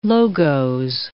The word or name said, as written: logos